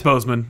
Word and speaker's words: Boseman: Chadwick Boseman, or Bosman? How is it pronounced? – It's Boseman, I think.